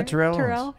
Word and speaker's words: Terrell Owens: In addition to Jimmy, Darius Rucker, Terrell Owens. Is that how you say it? – Yeah, Terrell Owens.